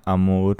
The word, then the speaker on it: Amour: Έχω στο την, ε, την Chloe, νομίζω έτσι διαβάζεται, Amour Ε, μήπως, α, μήπως ε, έχει και ένα L, μήπως λέγεται Chloe Lamour. – Όχι, είναι αμούρ.